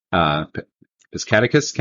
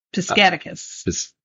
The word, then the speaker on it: Piscataquis: Piscataquis County. – Piscataquis. – Piscataquis.